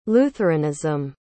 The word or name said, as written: lutheranism